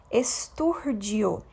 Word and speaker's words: estúrdio: Essa é uma palavra que possui acento agudo na antepenúltima sílaba. É, portanto, uma proparoxítona, esturdio.